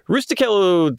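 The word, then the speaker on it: Rustichello: His cellmate in the tower is a dude named Rusticello, which I don't know if the CH is a hard CH or a soft CH. – It's a hard C, yeah. – Okay, Rusticello.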